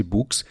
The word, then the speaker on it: Boox: parece ser justamente esse Books, que se escreve B-O-O-X.